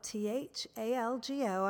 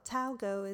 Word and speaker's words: Thalgo: It's spelled T H A L G O. I've always called it Thalgo, but I'm told Talgo is the way to say it.